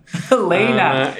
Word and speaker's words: Heléne: I love that you emphasize the pronunciation of Helena. – Helena.